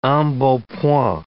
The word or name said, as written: embonpoint